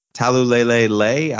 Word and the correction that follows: Taulelea: I believe that's pronounced Champ Taulelea. – Taulelea.